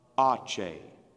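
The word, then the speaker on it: Aceh: So the first one on there was A-C-E-H. A-C-E-H. I didn't even know how to pronounce it. So I went to, you know, YouTube. They've got everything on there, you know. And over I learned how to pronounce Ache. Say it with me. Ache.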